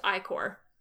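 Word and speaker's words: ichor: Isn't it pronounced Icker? – I thought it was pronounced Ikor. – In Taz, don't they say Icker? Do they say it wrong? Do I say it wrong? – Yeah, the internet tells me it's pronounced Ikor.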